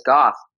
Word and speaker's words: Gough: Because when I moved here, I learned it was Gough like cough, not go like dough. – No, it's Gough.